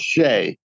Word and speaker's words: Xie: He spells it X-I-E. It's pronounced Shea.